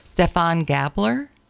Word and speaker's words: Stephan Gabler: Stephan Gabler.